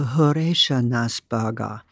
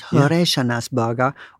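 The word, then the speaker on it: Horeshanas Burger (フレッシュネスバーガー): Yeah, in Tokyo, I observe it, and then I go to Horeshanas Burger, freshness burger, and that's how it's pronounced, Horeshanas Burger or Moss Burger, and they're great.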